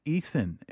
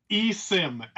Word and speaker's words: eSIM: Am I saying it eSIN? And how would I know, if it's supported by my phone carrier? Sorry, you were saying it is. How do you say it? – Yep. eSIM, S-I-M. So it stands for Electronic Subscriber Identification Module.